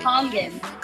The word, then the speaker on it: Kangen: And you can put your, how do you say it properly? You have to make sure we say it properly. – Kangen. – Yeah, we say Kangen.